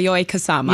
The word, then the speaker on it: Yayoi Kusama: Yes, Yayoi Kusama.